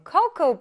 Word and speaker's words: cocoa: 근데 선생님이 이 단어 읽을 때 코코아라고 안 읽고 어떻게 읽었어요? Cocoa beans. 맞아요. 코코우라고 발음이 됩니다.